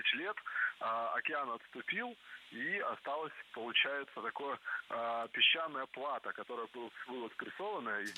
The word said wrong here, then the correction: плато: Не плато, а плато. Слово это тоже французское и тоже сохраняет ударение на последнем слоге.